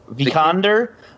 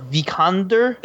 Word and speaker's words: Vikander: So he said Alicia Vikander. So she plays Laura Croft. Vikander. Alicia Vikander. – There you go. Okay. Say it like that every time.